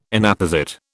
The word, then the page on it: Inapposite: British English